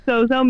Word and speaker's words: SOZO: And I was looking up, so this is very interesting because there's a, I thought SOZO is S-O-Z-O. Is that how you pronounce it? – It is. – Because there's the old demon that's Z-O-S-O.